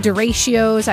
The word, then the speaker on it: derecho: Talking derechos. I said it wrong yesterday. Derecho apparently is how you say it.